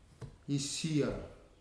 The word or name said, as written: isia